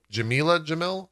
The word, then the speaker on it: Jameela Jamil: Uh, the first one being that uh, the Good Place actress uh, Jameela Jamil, I hope Jameela Jamil, I, I hope I'm pronouncing that correctly. – Um, yep, kind of has joined.